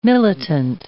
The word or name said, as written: militant